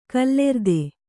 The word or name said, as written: ಗೋಲಾರ್ಧ